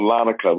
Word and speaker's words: Larnaca: Did I say it right? – Um, I, I, don't get me to lie. Uh, I haven't said it in so long. I, I just know we was on Larnaca.